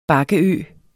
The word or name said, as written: bakkeø